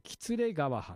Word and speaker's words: 喜連川: で意外にもですね、格付けにも例外があったというふうに言われておりまして、栃木県にあった喜連川藩というのがあります。喜ぶに、連動の連と書いて喜連川藩、これ国高がわずか 5000 石です。